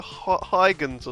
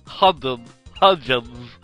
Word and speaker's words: Hudgens: How do you say Hudgens? – Hudgens.